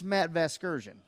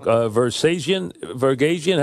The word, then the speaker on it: Vasgersian: But here's how you say his name. It's Matt Vasgersian, just like that.